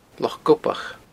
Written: Loch Gobach

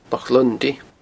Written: Loch Lunndaidh

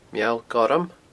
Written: Meall Gorm

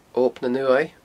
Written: Ob nan Uaimh

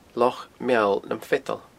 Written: Loch Meall nam Feadan

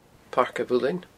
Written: Pairc a' Mhuilinn

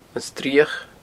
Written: Srioch

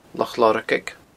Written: Loch Lorgaig

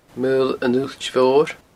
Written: Maol an Uillt Mhoir

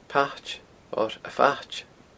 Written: Pait

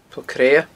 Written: Poll Creadha

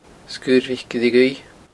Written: Sgurr Mhic Ille Dhuibh

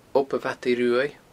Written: Ob a' Mhadaidh Ruaidh

